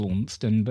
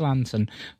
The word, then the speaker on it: Launceston: It's spelt as Launceston, but pronounced Lanson.